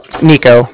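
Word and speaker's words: Niko: Nico, that's N-I-K-O.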